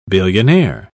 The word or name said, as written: billionaire